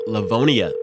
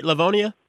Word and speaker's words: Livonia: Did I say it right? Livonia? – Yeah. Yep. You got it right.